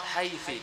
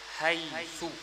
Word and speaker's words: حيث: If you pronounce Haythi, this is wrong. That's why there is no any qira'ah, no any riwayah with Haytha or Haythi. All the qira'ahs, Haythu.